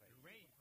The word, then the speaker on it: Raye: Ray. Ray. Se escribe Raye. Raye, con ella.